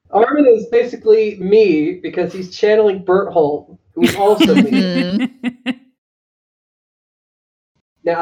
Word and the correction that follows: Bertolt: Every time you say his name, it sounds like you're saying butthole to me. – Bertolt. Gotta say it right.